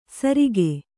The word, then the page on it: ಸರಿಕೆ: Kannada